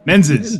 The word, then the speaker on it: Menzies: Menzies. Did I get it? – Menzies, yeah. Yes. So I think actually we might have discussed this last time. So it's Menzies is the anglicized way of pronouncing it.